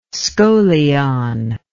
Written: scolion

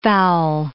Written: fowl

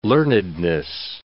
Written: learnedness

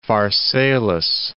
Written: Pharsalus

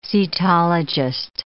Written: cetologist

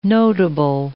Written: notable

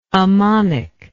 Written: ammonic

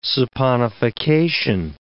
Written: saponification

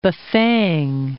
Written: Bafing

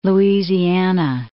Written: Louisiana

